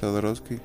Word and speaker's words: Jodorowsky: ¿Se pronuncia así, Sodorowski? – Sodorowski, ajá.